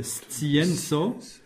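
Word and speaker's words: scienco: Alors la difficulté de prononciation, scienzo, S suivi de C, il faut entendre S, T, S, scien. – Oui. – Et ensuite, il y a un nouveau C, tso, scienzo.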